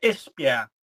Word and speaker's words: Esbjerg: I don't think I'm pronouncing that correctly, but it's on the western coast of the country. – Iceberg? – Yeah, Esbjerg. – Esbjerg. Yeah. Okay. – Oh, that was very good.